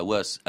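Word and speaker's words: Wace: Alors les auteurs, les deux premiers en tout cas, Monmouth et, et, Was, c'est ça, c'est comme ça qu'on prononce son nom. – Was, oui, c'est un anglo-normand, donc faut prononcer à l'anglo-normand.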